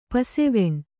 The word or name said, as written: ಪಸೆವೆಣ್